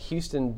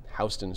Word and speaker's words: Houston: Lagrange turned a Houston boogie band into the biggest groups on the planet.